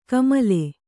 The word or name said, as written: ಕಾಮಿಲೆ